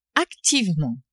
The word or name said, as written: activement